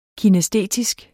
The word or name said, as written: kinæstetisk